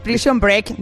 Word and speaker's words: Prison Break: ¿Cómo se dice? – Prison Break, diría break. Yo, pero bueno.